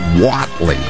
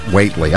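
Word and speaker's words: Whatley: Says Watley is pronounced Watley, not Waitley.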